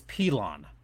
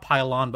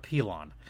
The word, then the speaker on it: Pilon: And I want to make sure I have this pronunciation correct. It's Pilon. Not Pilon, but Pilon.